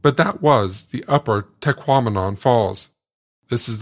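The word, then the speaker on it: Tahquamenon: But as I learned from a sign within the park, one easy way to get it right is to remember that it rhymes with phenomenon, as in Taquamenon.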